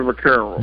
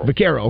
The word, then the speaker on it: Vaccaro: And I want to imagine, is it Vaccaro or Vaccaro? What's the correct pronunciation? – It's Sonny Vaccaro. – Vaccaro, okay. So tell us about your relationship with him.